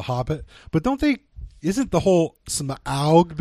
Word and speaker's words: Smaug: I've been pronouncing it wrong my whole life. I pronounced it as if it was the pollutant smog, but it is, according to the film, Smaug.